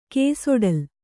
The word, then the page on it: ಕೇಸೊಡಲ: kēsoḍala